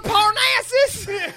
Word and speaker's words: Parnassus: Parnassus? – Parnassus. – Yeah. That's what I was going to say next. – It rhymes with molasses. – Captain Phasma, are you from Parnassus? – Parnassus.